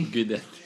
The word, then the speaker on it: Guidetti: Guidetti. – Det ska vara nästintill ett V i uttalet om jag inte missminner mig. Så att ett UI på italienska blir ett G.